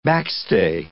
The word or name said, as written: backstay